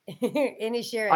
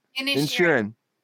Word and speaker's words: Inisherin: And then the big ones for Best Picture, The Fabelmans, for Drama, Musical, or Comedy, Best Picture, The Banshees of, how do you say it? Inisherin? – Inisherin. – I can't. – Inisherin. – Yeah.